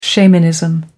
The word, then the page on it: shamanism: American English